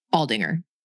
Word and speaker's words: Aldinger: Aldinger. – Aldinger. So it's like, yeah. Okay, a hard G.